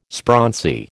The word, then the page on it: sprauncy: American English